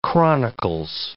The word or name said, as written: Chronicles